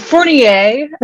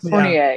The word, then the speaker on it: Fournier: Fournier, actually. – Okay. – The A is the only French part we've retained, but Fournier, yeah.